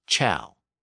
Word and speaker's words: ciao: That word became C-E-N-T-O in Italian, but it was pronounced cento with a CH sound. Also think about the word C-I-A-O in Italian. which is pronounced as chow.